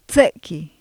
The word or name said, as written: tse7ki